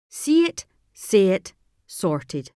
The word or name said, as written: See it say it sorted